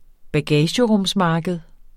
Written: bagagerumsmarked

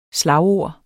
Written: slagord

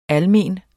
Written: almen

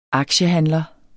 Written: aktiehandler

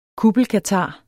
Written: kuppelkatar

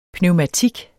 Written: pneumatik

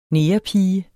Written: negerpige

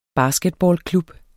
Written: basketballklub

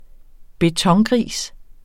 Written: betongris

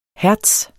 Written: hertz

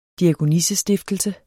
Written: diakonissestiftelse

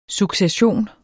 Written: succession

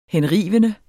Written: henrivende

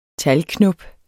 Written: talgknop

